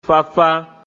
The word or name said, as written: Făfá